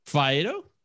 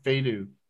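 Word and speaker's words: Faedo: How are you actually supposed to say it? – Fido. – I always said Fedu. – Yeah, Fedu.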